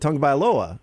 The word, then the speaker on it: Tagovailoa: No, but you pronounce it Tagovailoa. You start with the tongue.